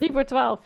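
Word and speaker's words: 3voor12: Drievoor 12? How do you say 12? – Drievoor 12, yes.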